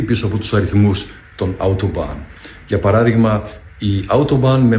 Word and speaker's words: Autobahn: Καταρχάς, να το προφέρουμε σωστά. Λέγεται Autobahn και όχι Autobahn, όπως το προφέρουμε.